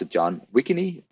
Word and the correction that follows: Wegovy: I think it's called. I think it's actually Wegovy.